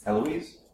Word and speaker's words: Alois: Alois? – A-L-O-I-S. – Alois? Alois. If it's French.